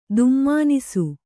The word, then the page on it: ದುಮ್ಮಾನಿಸು: Kannada